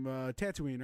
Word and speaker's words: Tatooine: So I think it would be cool to see him on a, oh my God, I'm about to butcher the name, Tatooine, right? – Yeah, you're good.